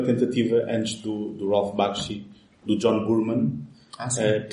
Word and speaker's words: Bakshi: Bakshi. – Bakshi, exato.